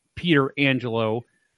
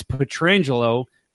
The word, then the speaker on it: Pietrangelo: Actually, Alex's dad was asked, "How do you pronounce it?" And he said, "It's Pietrangelo." Frank, uh, he did it wrong. He pronounced it incorrectly.